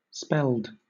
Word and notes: spelled: British English

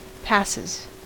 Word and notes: passes: American English

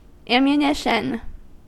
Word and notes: ammunition: American English

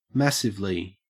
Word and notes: massively: Australian English

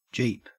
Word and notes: jeep: Australian English

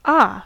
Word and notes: ah: American English

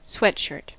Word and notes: sweatshirt: American English

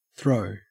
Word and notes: throw: Australian English

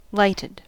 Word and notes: lighted: American English

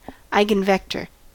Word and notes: eigenvector: American English

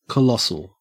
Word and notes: colossal: Australian English

